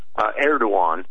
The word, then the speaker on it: Erdogan: But yeah, they pronounce it in Turkish. I always say, just think it Erdogan. – Rhymes with say Erdogan Ben Kenobi. If you're a Star Wars fan, it rhymes. – All right. – It rhymes with Obi Wan.